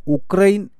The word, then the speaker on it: ഉക്രൈൻ: ആകാശവാണി വാർത്തയിൽ ഉക്രൈൻ എന്നാണ് പറയുന്നത്. ശരിയോട് കൂടുതൽ അടുത്തു നിൽക്കുന്നത് അതാണെന്നാണ് റഷ്യൻ അക്ഷരവും ഉച്ചാരണവുമൊക്കെ അറിയുന്നവർ പറഞ്ഞു തന്നത്.